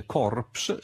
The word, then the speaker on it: corpse: Notate che ad esempio anche oggi in inglese corpse, scritto in questo modo anche se la P è muta e non si pronuncia, e in realtà corpse significa semplicemente cadavere.